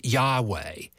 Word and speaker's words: Yahweh: One of them is Yahweh, and this is often incorrectly pronounced as Jehovah, and the other one is El.